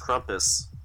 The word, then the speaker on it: Krampus: Nick, it's pronounced Krampus.